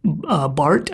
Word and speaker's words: Barthes: based on Death of the Author by Roland Barthes, I think is how you say his last name. It's French. It's spelled B-A-R-T-H-E-S, and he's like this theorist, post-structuralist guy, I think.